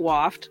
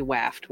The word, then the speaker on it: waft: I always pronounced it waft because that sounded right in my brain, but it's actually waft when you waft a, a smell I, waft.